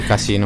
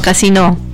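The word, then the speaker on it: Casinò: Sì, sì, Casino. – No, Casino, con l'accento sulla O.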